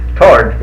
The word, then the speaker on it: toward: Tord is proper. T-O-A-R-D is the only part of the sound in that. The W is silent.